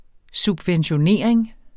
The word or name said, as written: subventionering